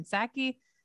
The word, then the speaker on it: Psaki: The existence of the after-action reports contradicts claims made Friday by White House Press Secretary Jen Psaki. Is it like pterodactyl? P is silent.